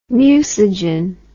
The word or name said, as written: mucigen